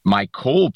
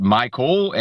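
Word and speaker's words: MyCole: Michael Pruitt, is that how you pronounce his first name? – Mykole Pruitt. – Okay, Mykole Pruitt. – His name is pronounced Mykole and played for Arthur Smith last year in Atlanta.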